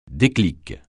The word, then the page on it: déclic: French